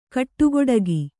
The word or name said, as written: ಕಟುಗೊಡಗಿ